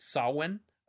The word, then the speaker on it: Samhain: Uh, the the actual pagan holiday and uh how what are they uh it's spelled Samhain but it's Samhain